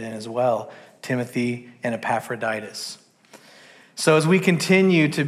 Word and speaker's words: Epaphroditus: We're going to come across the name that's pronounced Epaphroditus. On the count of three, I want everybody to say Epaphroditus. One, two, three. – Epaphroditus. – Beat the nine, I think. Beat the nine. Sure. When you come across Epaphroditus, that's how you say it.